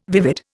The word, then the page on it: vivid: Indian